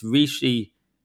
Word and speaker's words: Rishi: It's Rishi, Rishi. It's Rishi with two I's, as in Fishy Rishi.